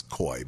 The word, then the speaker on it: Coie: I don't know why it's pronounced Coie. It's Coy, but never mind.